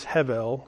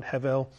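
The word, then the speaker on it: hevel (הֶבֶל): So the word is hevel, and it's spelled with a B in English, but it's pronounced with a V sound, hevel.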